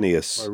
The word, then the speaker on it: Quirinius: Quirinius.